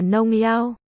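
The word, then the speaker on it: nông yao (农药): Hai, vinh diệu đọc là rong yao, thuốc trừ sâu đọc là nông yao.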